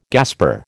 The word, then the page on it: gasper: American English